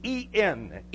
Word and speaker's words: en: The word in the Greek is en, en. It's pronounced in, and it means in.